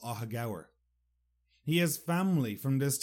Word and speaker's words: Aughagower: Well, before I tell you why, I'd like to tell you some facts about Ahagour. And first, how you spell it. A-U-G-H-A-G-O-W-E-R. Ahagour. Just so you can see why I was in such disbelief. Ahagower is a tiny village located in the west of Ireland outside a bigger town called Westport.